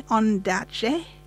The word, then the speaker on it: Ondaatje: Okay, pronounce this for me. Ondaatje. – You got it.